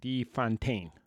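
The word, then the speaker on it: de Fontaine: So, Elaine plays Valentina, Allegra Di, how do you say it, Di Fontaine. – Yes. – That's how it's said.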